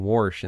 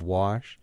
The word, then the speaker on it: wash: I insisted on saying Warsh instead of Wash. I actually started that back in Hudson because my mother, who was an English teacher, loathed that pronunciation of Wash. But it's a West Virginia pronunciation, and you do hear it a fair amount in Ohio.